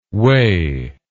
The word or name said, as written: wae